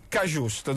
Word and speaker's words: Cajuste: Ho letto che il padre però, pur essendo americano, è di origini haitiane, che era una colonia francese, quindi secondo me si pronuncia alla francese come Saint-Just, esponente della rivoluzione francese, quindi Cajuste dovrebbe essere alla francese.